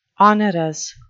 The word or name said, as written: onerous